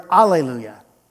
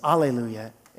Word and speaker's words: alleluia: How come sometimes we see the word alleluia, right? Maybe in the old hymnals, alleluia, instead of the H. Did we just get lazy in our pronunciation? No, alleluia is the Greek version.